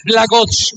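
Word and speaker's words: Blagoje: Se debe pronunciar Blajoch, como lo hizo Mauricio.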